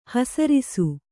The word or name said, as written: ಹೆಸರಿಸು